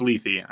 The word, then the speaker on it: Lethe: I'm pretty sure it's Lethe. I'm pretty sure they pronounce Lethe. It. At least in the game, they pronounce it Lethe.